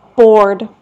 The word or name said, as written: bored